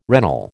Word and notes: regnal: American English

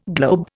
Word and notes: globe: Indian

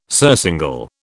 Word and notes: surcingle: British English

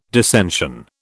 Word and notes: dissension: British English

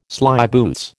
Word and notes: slyboots: American English

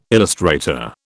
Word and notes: illustrator: British English

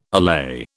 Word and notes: allay: British English

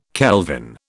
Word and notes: kelvin: British English

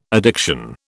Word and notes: addiction: British English

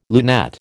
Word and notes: lunette: American English

